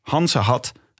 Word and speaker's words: Gansengat: Tijdens carnaval wordt Goes eigenlijk omgedoopt tot Hansehat. Eigenlijk Gansengat. Maar in Goes wordt de G uitgesproken als een H.